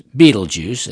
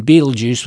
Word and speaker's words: Betelgeuse: The most popular pronunciation can be rendered as Betelgeuse, as I've said, making the star the namesake of a popular movie released in 1988. While it's more common to pronounce the first syllable in Betelgeuse with a long E, like the root vegetable, it's also acceptable to pronounce with a short E, like the word for placing a wager. Also, pronounce the last syllable with a Z sound, like Jews, rather than the more common S sound.